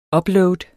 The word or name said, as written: upload